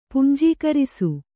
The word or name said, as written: ಪುಂಜೀಕರಿಸು